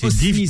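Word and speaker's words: Osny: Alors Oni, c'est Osni, ça s'écrit Osni.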